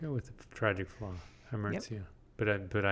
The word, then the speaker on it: hamartia: It's hamartia. – I think this is pronounced hamartia, though I have never heard it actually said. – I'm thinking of omertia. – Hamartia. Tia, yeah.